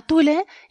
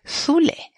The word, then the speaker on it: Tule: Tule, en español lo podemos pronunciar eh, con nuestra T normal porque lo hemos tomado del latín. Aunque, como la palabra es de origen griego, si realmente queremos ser muy puristas tendríamos que decir Zule, con esa Z medio sibilante que, bueno, a quien le sale realmente bien, después de a los griegos, es a los canarios.